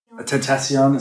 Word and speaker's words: XXXTentacion: Tentacion, is how you pronounce it.